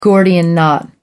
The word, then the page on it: gordian knot: American English